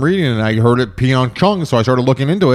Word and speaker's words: Pyeongchang: The name of Pyeongchang, which is holding the 2018 Winter Olympics, is Pyeongchang. Mm-hmm. Pyeongchang is how you pronounce it.